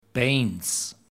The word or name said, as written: Bains